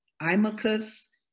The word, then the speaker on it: Imahkus: Imacus, Nzinga, Okofu, Ababio.